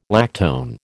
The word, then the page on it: lactone: American English